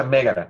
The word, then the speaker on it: Megara: Eh, se pronuncia Megara.